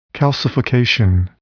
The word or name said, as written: calcification